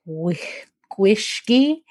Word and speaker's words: gwyllgi: So it's spelled GWYLLGI. And from best I can tell, Gwishgi, and that's a black dog of Wales.